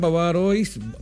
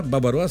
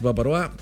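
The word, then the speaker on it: bavarois: De postre tenemos jalea, flan, babarois, babacuá, babaroa se pronuncia, ¿cierto? Babaroa, se escribe babarois, babaroa.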